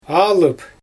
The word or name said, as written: aalyp